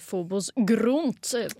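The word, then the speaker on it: fobos eh, grunt (Фобос-Грунт): Ja, jeg kan også si at det eh, uttales fobos eh, grunt på, oh, på russisk.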